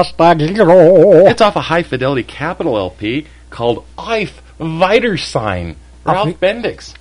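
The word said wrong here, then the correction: Auf Wiedersehen: I think it's off Weidersein. – Did I say it wrong?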